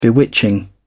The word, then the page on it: bewitching: British English